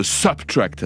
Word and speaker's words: SBTRKT: sous le nom de Subtract, ça s'écrit S-B-T-R-K-T.